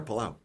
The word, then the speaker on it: Palau: Am I saying that right? – Yes, you are, Palau.